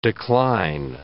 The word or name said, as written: decline